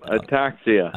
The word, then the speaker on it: ataxia: ataxia? – Yeah. Yep, ataxia.